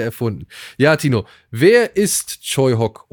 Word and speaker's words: Tsui Hark: Den kennt ihr eher vielleicht geschrieben als Tsui Hark, aber er wird tatsächlich Choi Hock ausgesprochen.